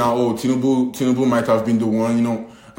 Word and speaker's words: Tinubu: Is that how you say his name? Tinubu? Yeah, from things I've heard about him, I don't think Nigerians have, you know, clocked that he's the president.